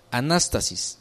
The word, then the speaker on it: anastasis: La resurrección, esta palabra resurrección viene del griego anastasis, anastasis, así como suena, con acento en la segunda A, anastasis.